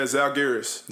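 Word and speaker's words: Žalgiris: Yeah, Zalgiris. Well, or you could say Zalgiris. That's what Lithuania said.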